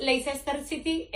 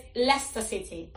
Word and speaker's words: Leicester: El primero no es Leicester City, es Leicester City.